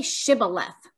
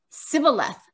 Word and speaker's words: Shibboleth: When he said no, they said to him, Say Shibboleth, and he said Sibboleth, for he could not pronounce it right.